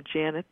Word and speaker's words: Janet: And interestingly enough, with the name Janet that rhymes with planet, I have a product called Janet's Planets.